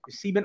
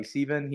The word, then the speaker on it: Sieben: So but very excited today because we have with us Darby Sieben. Actually, how do you say your last name, Darby? – You got it right there, Rit. – Sieben's right. Yep. Okay. Yeah, we have with us Darby Sieben.